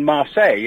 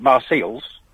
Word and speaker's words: Marseille: And then Marseille in South France, we don't call it Marseilles.